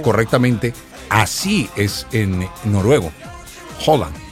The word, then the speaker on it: Haaland: Pero en realidad es Haaland. Es el nombre del jugador, o así se pronuncia correctamente el nombre del jugador, que se come 6,000 calorías diarias el man.